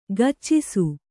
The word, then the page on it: ಕೆಚ್ಚಿಸು: keccisu